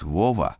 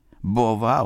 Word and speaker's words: Бова: Бова? Может, Вова? Ну, там одну букву перепутали, бывает же. Нет, Никитушка, Бова он и есть Бова, с ударением на последнюю букву.